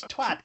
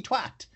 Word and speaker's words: twat: You're saying it wrong. What are you doing? Like, it's twat. It's clearly twat.